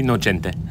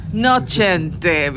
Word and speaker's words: Nocente: Am I pronouncing that right? – Can be Nocente. – Nocente, because it's a little bit of an Italian spot that we've got here.